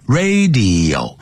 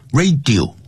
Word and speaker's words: radio: serious 啊，不要念成 serious，常常听了这我们中国人常常念成 serious 啊， serious 啊， serious，第二念 serious，就像收音机叫 radio，不要念成 radio， radio， serious， serious， radio，啊，这样念 radio，像影像呢，视频叫 video， video，不要 video 啊， video， radio， serious， serious problems to humans，对人类啦， plants。